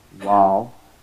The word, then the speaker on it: waw: Now, it is important to remember that the vowels in Arabic are three in total, and those three are waw, the alphabet waw, and that's how we pronounce it, waw, and the alphabet ya.